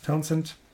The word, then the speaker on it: Townshend: Townshend spricht man es, glaube ich, aus. Townshend.